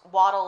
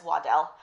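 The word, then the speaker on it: Waddel: It is pronounced Waddle like a duck. Later on, they added an extra L, and it became pronounced as Waddell. We have a Waddell Street, and people get confused about that extra L.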